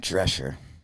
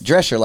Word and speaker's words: Drescher: Drescher, like the nanny, like Fran. Drescher?